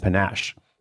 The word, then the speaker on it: panache: By the way, panache is a fun word, but I'll be honest with you, I had to look it up to make sure I was saying it correctly, and I am.